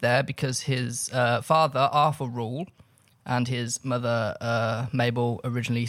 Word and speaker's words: Reuel: Rule. R-E-U-E-L. – Rule. – Rule.